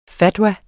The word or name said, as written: fatwa